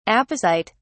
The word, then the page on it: apocyte: British English